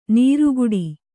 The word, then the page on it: ನೀರುಗುಡಿ: nīruguḍi